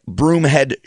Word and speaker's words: Broomhead: Again, if you're a social media user, on Twitter, I am at Broomhead Show, and my last name is spelled just like it sounds.